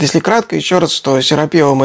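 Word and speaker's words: Серапеум: Я просто для уважаемых зрителей напомню, ну, во-первых, чтобы зрители не ждали, что я сейчас буду рассказывать опять про Серапиум, все-таки я настаиваю, что ударение ставится в этом слове так, Серапиум.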